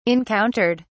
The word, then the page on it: encountered: British English